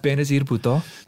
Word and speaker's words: Benazir Bhutto: Benazir Bhutto.